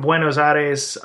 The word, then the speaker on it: Buenos Aires: Yeah, I'm going from Buenos Aires, Argentina, to Santiago, Chile.